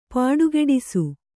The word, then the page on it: ಪುಡಿಗುಟ್ಟಿಸು: puḍiguṭṭisu